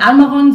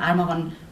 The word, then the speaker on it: Amaron: Ich habe gemerkt, nein, ich finde es auch tatsächlich besser, wenn mein richtiger Name so ausgesprochen wird, wie er von Anfang an gedacht war, nämlich Amaron, sodass ich mich jetzt auch seit einigen Jahren tatsächlich mit Amaron vorstelle und es auch aber nicht schlimm finde, wenn der nicht immer richtig ausgesprochen wird.